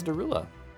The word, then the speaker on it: Derulo: Is Jason Derulo, but it's spelled like. – Differently? – Ridiculously. DESROULEAUX. – Well, that's not Derulo. – That's Derulo. Mm-hmm.